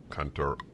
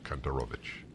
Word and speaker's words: Kontorovich: K-O-N-T-O-R, Kantorovich, Kantorovich.